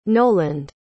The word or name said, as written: noland